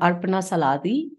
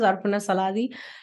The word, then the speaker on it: Arpana Saladi: We have a YouTube channel, which is called Arpana Saladi. And then we have an Instagram handle, which is called as Arpana Saladi. So it's all the same, Arpana Saladi. I know it's difficult to pronounce my name, but it spells A-R-P-A-N-A-S-A-L-A-D-I.